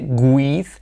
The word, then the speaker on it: with: No se dice with. Y por supuesto la I como E. With. Y la TH semiclave también no es, no es with, sino with. Como una Z española.